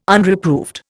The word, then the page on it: Unreproved: Indian